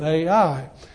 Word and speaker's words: Ai: First are the initial battles for two major cities, the city of Jericho and the city of Ai, which is spelled just like you say it, Ai.